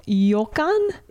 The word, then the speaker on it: ryokan: R-O-A-K-A-N. Ryokan just means like bathhouse. – It's pronounced Ryokan? – Yo. It's a sound that only exists in Japan. R-Y-O. – Uh-huh. – Like, is Yo? – The character from Street Fighter, like R-Y-U.